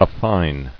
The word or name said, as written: Affine